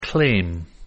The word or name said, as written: claim